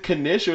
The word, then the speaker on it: knish: Is it knish or is it nish? I've heard it like different ways. – I think the K, you like kind of like skip on the K, like knish.